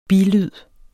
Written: bilyd

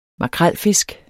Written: makrelfisk

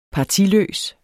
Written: partiløs

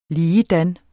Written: ligedan

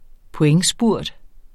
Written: pointspurt

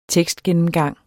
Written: tekstgennemgang

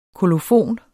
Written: kolofon

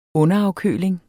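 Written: UNDERAFKØLING

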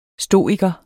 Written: stoiker

